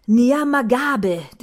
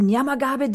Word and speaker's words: Nyamagabe: Nyamagabe district. It's the Nyamagabe district of southern province of Rwanda, and it's a washed red bourbon variety.